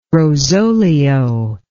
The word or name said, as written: rosolio